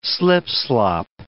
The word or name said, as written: slipslop